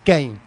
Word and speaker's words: Kane: Kane. Como Kung Fu. – Como las papas McCain, digamos.